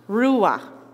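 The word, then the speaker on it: ruach: The word is ruah, pronounced ruah, okay? So there's a little bit of a ch at the end of it, which, you know, is a bit of a problem for me, but some of you Dutchies won't have a problem with it.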